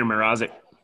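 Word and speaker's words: Mrázek: How do you say his name? Mrazek, right? – Peter Mrazek.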